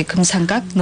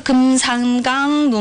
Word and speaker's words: 금삼각: 여기서 발음 들을 때는 금삼강이라고 들리거든요. 아, 네, 금삼각. 네. 네, 금삼각 눈부신. 네, 짜그 금삼강 눈부신 이렇게 들려요. 네, 여기서 금삼각, 발음 조심하시고요.